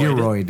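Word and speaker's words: Gearoid: G-E-A-R-O-I-D. I thought he was a Transformer. – It's like Geroid.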